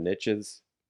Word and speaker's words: niche: Now, if you pronounce it niche, then that doesn't really rhyme, but the idea of like getting that audience. – Unless you call it riches. – Yeah, riches in the niches. Yeah, that doesn't work either. That one's for you, Brian Plum, if you happen to live. One of my buddies is from the UK, Brian Plum, and we had this whole discussion. He's like, you guys mispronounce it.